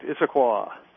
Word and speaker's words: Issaquah: Issaquah, Washington. Am I pronouncing that right? – Yeah, that's correct. Issaquah.